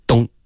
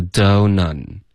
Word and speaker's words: don't: OK，东这个非常一字一顿，非常中式，是 don't OK，要连贯，不要东 OK，这个东是中文的发音，英文不存在。